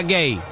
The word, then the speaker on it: Guehi: Gay se pronuncia así, pero se escribe G-U-E-H-I. Pero se pronuncia Gay.